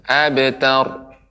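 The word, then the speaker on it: أبتر: Ro nggak boleh dipantul tidak boleh dipantulkan abtar ya tapi dia benar-benar diucapkan lepas ya dan ditafkhimkan abtar tar tar ya silakan ibu diulangi inna syani'aka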